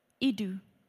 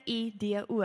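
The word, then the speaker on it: ido (εἴδω): Nou die woordjie nou in hierdie konteks is die Griekse woord eido of ido. En wat vir my amazing was, toe ek dit sien, dit word gespel E I D O, maar jy pronounce dit I kolletjie I do D O. I do.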